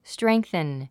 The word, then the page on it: strengthen: stréŋ k θən; ストレンクセン